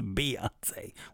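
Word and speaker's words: Beyoncé: And by the way, B-E-Y spells B, not Bay, you idiots. Her name isn't Beyonce, you fucking idiots. It's Beyonce. When you see B-E-Y, that's pronounced B, not Bay. Why would you say Bay, weirdos? Her name, it's Beyonce, B-E-Yonce. B-E-Y, if they pull the B-E-Y out, it's B.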